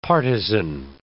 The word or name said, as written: partizan